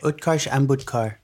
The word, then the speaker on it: Utkarsh Ambudkar: Utkarsh Ambudkar.